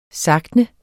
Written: SAGTNET

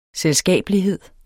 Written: selskabelighed

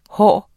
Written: hår

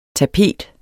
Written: tapet

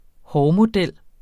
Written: hårmodel

